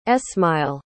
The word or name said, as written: esmail